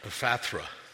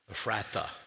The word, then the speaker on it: Ephrata: If you go online and hit the button how to pronounce Ephrata, you got it right. It's Ephrathra, Ephrathra. You did it really well, so thank you for that.